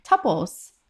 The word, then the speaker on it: tuple: So according to the Cambridge Dictionary, in the US, it's supposed to be pronounced as tuple. – Okay, so... – As well as the UK, so I'm wrong. So I would say tuple, but yeah, it's tuple.